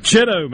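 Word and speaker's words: Bogue Chitto: I still can't pronounce Bogachito. Oh, man. – Chitto, man.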